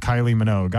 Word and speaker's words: Minogue: I feel like I have to tell you it's Kylie Minogue. I'm surprised that a gay didn't know that.